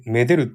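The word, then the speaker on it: 愛でる: そうだ、愛、愛って書いて、め、めでるって読むんだ。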